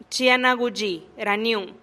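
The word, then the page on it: chi a na guxi raniun: Teotitlán del Valle Zapotec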